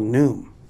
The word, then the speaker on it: Noom: He goes, I don't think that's how you say it. I think it's called Noom. I was like, don't tell me that. He said, because my mom does it, and it's called Noom. It's not Gnome. Because Gnome is a garden gnome.